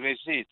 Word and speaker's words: Regis: Say it again. – Resist. – Okay. You have a question? – Yeah. Hello. – Yes, my name is Regis, R-E-G-I-S.